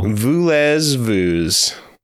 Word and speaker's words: Voulez-Vous: Tell me how to pronounce the title of this album. – Oh, you actually don't know? – No, I do. – Voulez-vous.